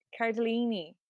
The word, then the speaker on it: Cardellini: I was saying Linda Cardinelli. Her name is Linda Cardinelli? – Cardellini. – Cardellini.